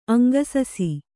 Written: ಅಂಗಸಸಿ

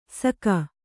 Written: ಸಕ

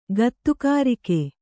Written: ಗತ್ತುಗಾರಿಕೆ

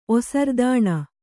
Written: ಒಸರ್ದಾಣ

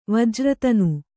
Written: ವಜ್ರತನು

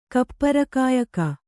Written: ಕಪ್ಪರಕಾಯಕ

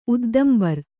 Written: ಉದ್ದಂಬರ್

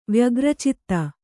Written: ವ್ಯಗ್ರಚಿತ್ತ

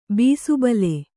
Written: ಬೀಸುಬಲೆ

